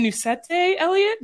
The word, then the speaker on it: Nucete-Elliott: And so we're excited to introduce a businesswoman and someone I'm really lucky to call friend, Andrea Nucete-Elliott.